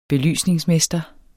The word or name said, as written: belysningsmester